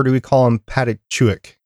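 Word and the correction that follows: Pachakutiq: That's I don't think that's how it's pronounced. How do you think it's pronounced? – Hold on, I gotta look it up. – It's It's P A C H A K U T I Q. – Okay, it's Pachakutik.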